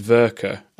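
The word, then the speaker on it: Werke: I'm going to tell you to go and look at Verka magazine. It's W-E-R-K-E, but it's pronounced Verka,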